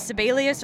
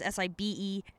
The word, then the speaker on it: Sibelius: Another program that is similar to Finale, but offers a slightly different aesthetic and final important package, is Sibelius or Sibelius. You'll hear different pronunciations. That is Sibelius.